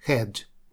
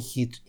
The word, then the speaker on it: head: Здесь мы говорим «head», не «hit», ни в коем случае.